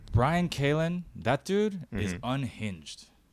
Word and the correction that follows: Callen: This is the reason I don't really follow that whole group, is that I could tell because that's not how you say his name either. – What is it? – Brian Callen.